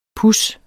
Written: pus

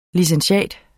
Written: licentiat

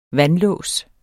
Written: vandlås

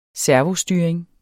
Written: servostyring

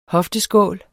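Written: HOFTESKÅL

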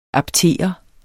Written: aptere

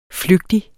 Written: flygtig

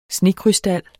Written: snekrystal

